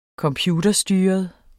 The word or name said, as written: computerstyret